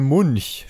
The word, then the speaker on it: Munch: Und da beginnen wir mit der Episode 774, der verschwundene Schrei von Munch. Spricht man, glaube ich, so aus, ne? – Ja.